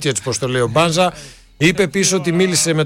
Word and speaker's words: Μπάνζα: Ακούσατε στην αρχή, Μπάνζα ο ίδιος, και καλά κάνει εδώ ο ΠΑΟΚ και τον αφήνει να συστηθεί, να πει ο ίδιος πως προφέρεται. Άρα Μπάνζα, όχι Μπάνζα, τον παίρνουμε τον τίτλο από τη Λίγουσα και τον πάμε σε αυτό, πως λέγεται το προηγούμενο, Παραλίγουσα, ξέρω εγώ.